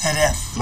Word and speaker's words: Jerez: We shot mostly in the south of Spain in a town called Jerez. – Oh, okay. – Yeah, Jerez. – Oh, like pronounced Jerez.